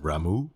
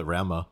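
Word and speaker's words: Ramuh: Total side note, I did not know you were supposed to pronounce it Ramu until I played this game, FF14. – What did you think that it was? – I was just called it Rama.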